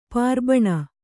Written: ಪಾರ್ಬಣ

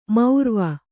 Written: ಮೌರ್ವ

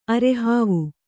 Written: ಅರೆಹಾವು

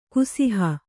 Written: ಕುಸಿಹ

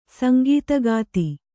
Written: ಸಂಗೀತಗಾತಿ

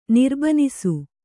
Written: ನಿರ್ಬನಿಸು

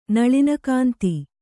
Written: ನಳಿನಕಾಂತಿ

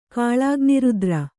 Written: ಕಾಲಾಗ್ನಿರುದ್ರ

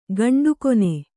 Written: ಕೆಂಡಗಣ್ಣ

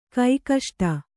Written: ಕೈಕಷ್ಟ

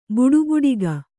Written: ಬಡಬಡಿಕೆ

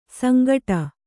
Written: ಸಂಕಟು

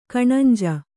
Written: ಕಣಂಜು

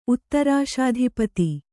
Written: ಉತ್ತರಾಶಾಧಿಪತಿ

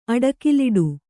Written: ಅಡಕಲಿಡು

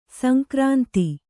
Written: ಸಂಕ್ರಾಂತಿ

